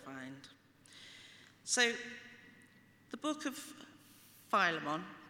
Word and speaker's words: Philemon: And apparently Philemon is supposed to be right, but also Philemon's okay.